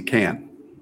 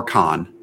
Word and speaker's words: Cannes: This music artist lets the Entourage crew fly in his private plane to Cannes or Con, depending on how you want to pronounce it.